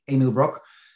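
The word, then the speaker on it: Emil Brok: Easiest on LinkedIn, look for my name, spell it correct, EMILBROK, Emil Brock.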